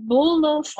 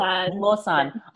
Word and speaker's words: Bulosan: Bulosan. – Oh, Bulosan.